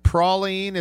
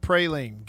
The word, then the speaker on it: praline: Several things learned in hour number one is that praline is how it's pronounced, not praline.